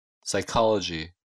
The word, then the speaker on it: psychology: Буква P не читается в следующих словах. – Cupboard. Psychology. Pneumonia. Raspberry.